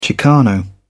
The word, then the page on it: chicano: British English